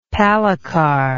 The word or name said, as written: palikar